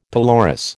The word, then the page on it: pelorus: American English